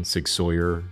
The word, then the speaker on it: Sig Sauer: I want to recognize Sig Sauer, and that's the correct pronunciation, Sig Sauer, Sig Sauer.